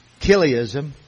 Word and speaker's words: Chiliasm: It was called Chiliasm, or you might pronounce it Chiliasm, but the CH is a letter key in Greek, Chiliasm, Chiliasm.